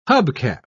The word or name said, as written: hubcap